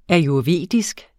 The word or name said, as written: ayurvedisk